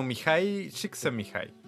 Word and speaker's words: Mihaly Csikszentmihalyi: Mira, se escribe como Mihaly Sixcent Mihaly. Y. Así. Eh, que se pronuncia como Mihai Csikszentmihalyi.